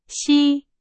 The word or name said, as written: 西